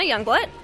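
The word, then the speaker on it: Youngblut: Jenna Youngblood, J-E-N-N-A-Y-O-U-N-G-B-L-U-T.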